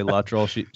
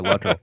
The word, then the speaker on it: Luttrell: My wife says I say everything wrong, including my last name. I say Luttrell.